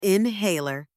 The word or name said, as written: inhaler